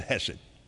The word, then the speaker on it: hesed: Now you'll see it with a C in front of it, hesed. But that's fine, just pronounce it hesed. H-E-S-E-D, now that's fine.